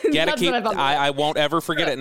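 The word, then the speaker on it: Goedeke: You've got the rookie, Luke Gedeki. Actually, I don't think that's how you pronounce it. You tell me, what's the pronunciation on Luke? – Gedeki.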